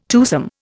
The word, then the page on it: twosome: Indian